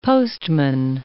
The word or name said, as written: postman